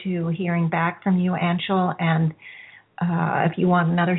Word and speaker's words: Anshul: So, it's Anshul, even though it's spelled A-N-S, it's pronounced Anshul, like U-N-S.